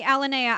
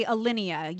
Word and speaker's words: Alinea: You say Alinea, I say Alinea. You're talking about the chef in Chicago, yes? – Yeah, right. Yeah, you're pronouncing it the right way. I'm doing it the French way.